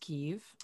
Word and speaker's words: Kyiv: She was an exchange student in Kyiv, which in the past couple of years we all learned is pronounced Kyiv, where there she worked as a fixer for the New York Times reporter, for a New York Times reporter called Bill Keller.